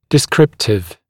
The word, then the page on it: descriptive: dɪ’skrɪptɪv; ди’скриптив